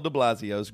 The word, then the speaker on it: de Blasio: The groundhog that escaped Mayor Bill de Blasio's grasp.